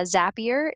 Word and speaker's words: Zapier: But uh, Zapier is a company that I followed for a few years. They're amazing. It's spelled Z A P I E R. Zapier rhymes with happier um, they are a software company and basically they are all about productivity for business owners and employees.